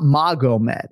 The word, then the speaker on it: Magomed: It's spelled M-A-G-O-M-E-D, but it's not Magomed. It's Magomed, right? – Right.